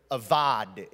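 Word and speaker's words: avad (עבד): All throughout Scripture, the word avad, it's spelled abad, but it's avad in the original language.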